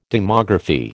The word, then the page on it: demography: American English